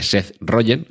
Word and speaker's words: Seth Rogen: Pero lo cierto es que, bueno, pues las, eh, los rasgos faciales de Walter Cronkite y de Seth Rogen, ahora lo he dicho bien, eh, pues bueno, pues tienen alguna similitud.